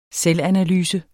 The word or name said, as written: selvanalyse